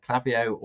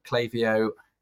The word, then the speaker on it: Klaviyo: In the, uh, there's pretty much one um, email provider that everyone uses in Shopify, and that's uh, Klaviyo or Klaviyo. People pronounce it differently.